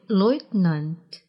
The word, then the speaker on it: Leutnant: В слове «leutnant» – «лейтенант» ударение напротив падает на первый слог.